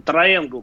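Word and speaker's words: Triangle: А второй вопрос – а, по поводу резины трианжил что вы скажете про нее? – Трианжил. Спасибо. – Давай. Начнем с последнего вопроса. Триангл, правильное произношение резины, то есть треугольник.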